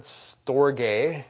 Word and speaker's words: storge: Then a third word for love is the word storge. That's how it's pronounced when it's a noun, at least.